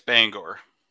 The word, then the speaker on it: Bangor: How do people pronounce Bangor from up there? Because I've heard it pronounced different ways. – It's Bangor. – Okay. So I've heard other people pronounce it differently. They're wrong.